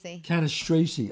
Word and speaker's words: Cannistraci: Cannistraci, Italian, from the Bronx.